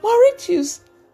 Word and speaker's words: Mauritius: Mauritius.